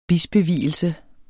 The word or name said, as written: bispevielse